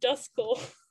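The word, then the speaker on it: Duskull: Because it's pronounced Dusculle. – Oh, really? – Yeah, because like dusk.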